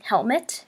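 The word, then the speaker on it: Helmut: Helmet. Helmet. Okay. Like helmet, like your head.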